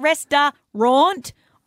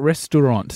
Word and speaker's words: restaurant: I'm like, is it rest-a-raunt? Or restaurant. – Oh, I see what you're getting confused with. No, it's spelt restaurant. – So R-E. I'm so sorry, Goldman Valley, to have to do this on the radio. But it's necessary that I accomplish this life skill. – If it's how you're saying, you'd pronounce it restant or. – No. – Yeah, if you switch them around. – Restaurant. – It's restaurant. – Restaurant. – R-E-S for the kids playing along. R-E-S-T-A-U-R-A-N-T.